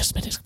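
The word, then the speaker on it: Smithwick's: And they tell me, well, I'd like a Smittix, please. It's spelled Smithwix, but they don't pronounce it that way.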